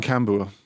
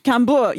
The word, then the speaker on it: Cambuur: You, you could get away with just saying Cambuur. – Cambuur, yes, that's how I tend to call them.